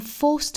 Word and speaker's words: forced: 后面 I'm forced to believe. Force 这个词后面加的这个字母 D 呢应该是读清辅音的 T 的发音 Forced.